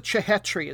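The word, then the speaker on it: Chhetri: Sunil, I'm going to pronounce it Chehetri. It's got the two back to back H's in there.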